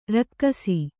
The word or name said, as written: ರೇಗಿಸು